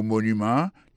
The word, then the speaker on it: monument: Pour le mot monument, il tombe sur le premier syllabe là.